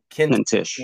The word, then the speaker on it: Kintish: So without any further ado, we have Benjamin Kitzman on. How are you doing, sir? – I'm good. Hanuman, I'm going to give it another try. It's Kintish. – Kintish. – It rhymes with mintish.